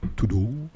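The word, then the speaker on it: Thibodeaux: Tudu. also buchstabiert T-H-I-B-O und dann D-E-A. A-U-X.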